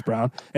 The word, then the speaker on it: Braun: Christian Brown has to do his thing, spelled like Braun, but it's Brown.